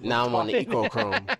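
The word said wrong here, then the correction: Echochrome: Yeah, because it's spelled Echo. – I know, but I keep thinking the E is like a long E.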